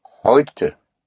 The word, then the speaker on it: Heute: Heute. – Heute. – Heute is spelled H-E-U-T-E. The E-U always has the sound of an oi.